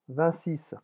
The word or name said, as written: vingt six